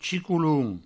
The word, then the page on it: chicolon: chicouloun; provençal